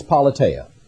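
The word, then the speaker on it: politeia (πολιτεία): The Greek word is politeia, P-O-L-I-T-E-A in the English language, the way we might pronounce it in Greek.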